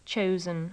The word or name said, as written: chosen